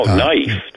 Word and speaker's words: naif: Oh, knife, they pronounce. – Oh, a knife? Okay. – Knife. – All right. – Knife. Knife. – Knife. – Knife. Right. It's how they say it.